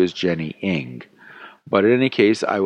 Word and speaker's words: Aang: you got the pronunciation right. Ing, spelled it I-N-G, or Aang, spelled it E-N-G, work. You should hear how it's pronounced in Chinese. Mark, did you get the pronunciation because Kim Aang was the Yankees assistant GM? Now, apparently, Steven has much more tolerance for multiple pronunciations than Alan. So, Alan, I apologize, and Steven, I apologize, and I will make every attempt to pronounce it Ing from now on.